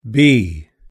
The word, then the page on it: bee: British English